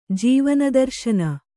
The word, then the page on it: ಜೀವನದರ್ಶನ: jīvana darśana